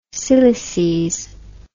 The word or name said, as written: cylices